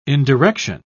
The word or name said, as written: indirection